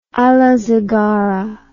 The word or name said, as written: alazingara